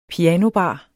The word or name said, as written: pianobar